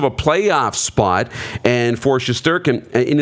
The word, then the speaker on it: Shesterkin: And news in New York, the Rangers are dealing with injuries, and the goaltender, Igor Shesterkin, how do you say his last name? – Shesterkin, yeah.